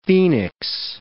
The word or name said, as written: phoenix